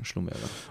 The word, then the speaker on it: Schlumberger: Ja, aber spannend, ich glaube, Schlumberger, ich habe gerade auch mal nachgeguckt, ist ja wirklich in Frankreich auch gegründet, wird sehr wahrscheinlich Schlumberger ausgesprochen. Ich wusste es so auch nicht, ich habe immer Schlumberger gedacht.